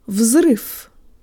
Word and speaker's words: взрыв: The last V sounds like an F. vzrif, vzrif, vzrif.